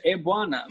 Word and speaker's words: Ebuana: Ebuana. Yeah, you say Ebuana. – Ebuana. Ebuana. Okay. – Pronounce exactly how you see it.